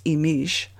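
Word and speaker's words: Imix: So as we begin a new 260-day cycle with one Imish, that's I-M-I-X, pronounced Imish, on May 14th, we're looking at the general idea of birth, the opening up of a whole new realm of possibilities.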